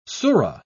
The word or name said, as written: sura